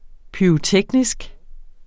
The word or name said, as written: pyroteknisk